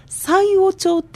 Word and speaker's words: 西尾町: そうなんです、私これあの西尾町って読むのかなってずっと思ってたんですが西尾町って読むんですね。